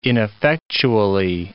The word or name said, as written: ineffectually